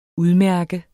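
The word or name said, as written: udmærket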